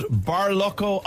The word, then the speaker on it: Barlocco: Tell us about Barlocco Island, and is that how you pronounce it? – It is indeed how you pronounce it.